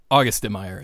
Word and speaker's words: Auguste: August de Meyer, I think. I think it's just like August the month.